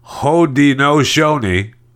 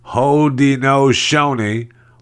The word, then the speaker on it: Haudenosaunee: Uh, Haudenosaunee, Haudenosaunee, Haudenosaunee, the Haudenosaunee Confederacy which is part of the Six Nations in Ontario, wasn't on the list.